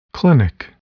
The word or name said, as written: clinic